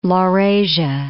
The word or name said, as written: Laurasia